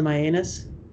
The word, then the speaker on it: Mianus: And everybody just calls it Mianus. It's spelled M-I-A-N-U-S.